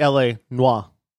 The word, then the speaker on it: Noire: And it's actually L.A. Noire. – I was going to say, I like to pronounce the R, but you do you.